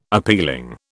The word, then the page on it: appealing: British English